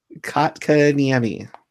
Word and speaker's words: Kotkaniemi: Kotkaniemi. – I didn't quite hear that. What was that? – Yeah, that's spelled Kotkaniemi. – And the way you pronounce it, it sounds like it's spelled Cockoniemi.